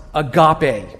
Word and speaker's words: agape: Now, one word for love is pronounced agape.